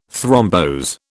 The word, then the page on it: thrombose: British English